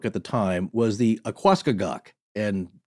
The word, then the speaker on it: Aquascogoc: In this case, it's spelled A Q U A, so like aqua, mm-hmm. and then C O G O C. So to me, Aquascogoc.